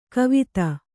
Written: ಕುವತ್ತು

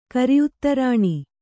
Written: ಕರಿಉತ್ತರಾಣಿ

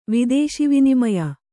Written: ವಿದೇಶಿವಿನಿಮಯ

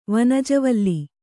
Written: ವನಜವಲ್ಲಿ

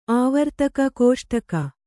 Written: ಕಸೊಡಿಗೆ

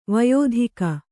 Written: ವಯೋಧಿಕ

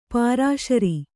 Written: ಪುರಃಸರ

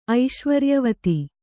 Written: ಐಶ್ವರ್ಯವತಿ

